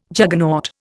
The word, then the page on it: Juggernaut: Indian